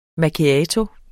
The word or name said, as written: MACCHIATO